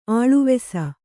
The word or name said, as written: ಆಳವಿಸು